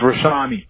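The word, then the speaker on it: Rashawni: It's Rashani.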